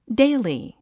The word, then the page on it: daily: déili; ディリィ